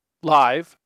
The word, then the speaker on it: live: All the other apps pronounced the four-letter word live incorrectly for the context, as if it rhymed with give. Audiobook Maker pronounced it correctly. Live rhymes with hive.